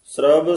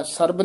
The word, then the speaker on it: श्रब: श्रब सिगार तंबो रस, ये श्रब बब्बे नाल हेठा रारा है, सरब नहीं कहना, श्रब सिगार तंबूल रस।